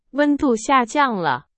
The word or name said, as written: 下降